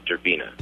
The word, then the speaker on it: Derpina: D-E-R-P-I-N-A, Durbina.